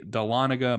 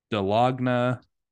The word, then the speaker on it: Dahlonega: I would say 95% of people do not know how to pronounce this ride. It is the Dahlonega Mine Train, the 1967 Mine Train, which it was the second mine train created.